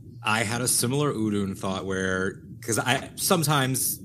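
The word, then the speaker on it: Udûn: Yeah, Udun.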